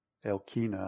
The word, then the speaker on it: Elkanah: Elkanah, I guess, is really how you say it.